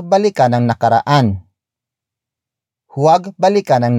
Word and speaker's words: balikan: And that action is balikan.